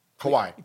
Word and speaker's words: Kawhi: Kawhi. – Kawhi. He's earned that. – Yeah, yeah. – That's what Kawhi. We established. Kawhi. He's earned, yeah. Okay. So I'm going to correct you every time. – Kawhi.